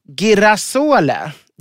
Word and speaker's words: girasole: Men det italienska ordet för solros är girasole. Girasole uttalas det nog.